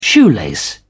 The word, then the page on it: shoelace: British English